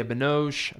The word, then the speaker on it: Binoche: The movie stars Robert Pattinson, Julia Binoche, I believe is how you pronounce her last name. – It is.